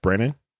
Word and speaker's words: Branagh: Kenneth Branagh.